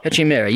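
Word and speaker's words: Katrien Meire: And the chief executive, is it, forgive me if I pronounce her name wrong, is it Karin Mitra? – Katrin Mira.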